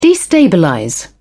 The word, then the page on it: destabilize: British English